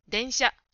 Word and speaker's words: 電車: 早く言っても電車、電車とんのところはちゃんと発音するようにしましょう。